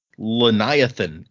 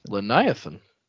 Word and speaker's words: Leniathan: And our second new patron is at the misting level, Leniathan. I pronounce it that way because it looks like Leviathan, but with an N in place of the V. So okay, Leniathan.